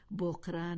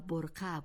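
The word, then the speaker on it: برقع: در کابل و شمال و مناطق مرکزی بدان چادری میگویند، ولی این کلمه در اصل بقره نبوده و برقه بوده است که کلمه است عربی.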